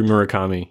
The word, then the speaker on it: Murakami: Ryu Murakami, yeah.